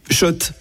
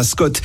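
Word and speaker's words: Schott: Oui, Shot. Et pas Scott. Ça fait des années qu'on prononce mal la marque.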